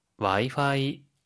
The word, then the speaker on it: Wi-Fi: Wi-Fi, Wi-Fi, Wi-Fi, Wi-Fi いくよ。